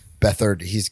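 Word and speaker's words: Beathard: CJ Bedford had a really rough start, but Bethard. – I said it wrong, but you're right. It's Bethard.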